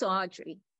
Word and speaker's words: Audrye: My name is Audrey. I spell it A-U-D-R-Y-E. If you need my last name, it's Arbe, spelled A-R-B-E.